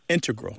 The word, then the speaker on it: integral: Just to clarify here, both correct, but the mathematicians and math-using scientists are more likely to say integral with the stress on the first syllable.